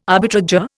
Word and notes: arbitrageur: Indian

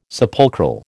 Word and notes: sepulchral: American English

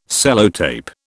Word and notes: Sellotape: British English

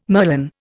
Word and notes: merlon: Indian

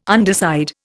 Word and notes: underside: Indian

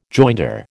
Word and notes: joinder: American English

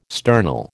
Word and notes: sternal: American English

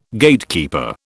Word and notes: Gatekeeper: British English

